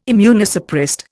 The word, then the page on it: immunosuppressed: Indian